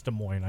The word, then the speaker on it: Des Moines: I know it's Des Moines. I just like having a little fun. – You're just, yeah, just good-natured to the folks in Des Moines.